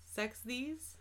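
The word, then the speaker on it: Sechs Kies: Yeah, it sounds like Sexies.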